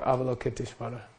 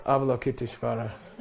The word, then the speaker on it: Avalokiteshvara: This angel is the embodiment of the compassion of all the Buddhas and is, in essence, Chenrei Sikh or Avalokiteshvara. Okay? When you can pronounce Avalokiteshvara, you know you're a real Dharma student.